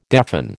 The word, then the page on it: deafen: American English